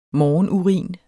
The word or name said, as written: morgenurin